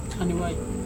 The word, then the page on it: haniwai: Serewen